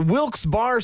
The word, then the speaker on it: Wilkes-Barre: And you're listening to Wilkes Barre Scranton's 97.9X. – Now, I had heard that it was supposed to be pronounced Wilkes-Barre. Wilkes-Barre Scranton's 97.9X. The only way to pronounce Wilkes-Barre.